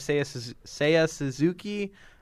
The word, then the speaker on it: Seiya Suzuki: So, Rookie of the Year, I have Seiya Suzuki.